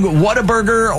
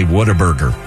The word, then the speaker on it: Whataburger: You say Whataburger, not Whataburger. – Right. – You say Whataburger. – Whataburger. – You just kind of put it all together there.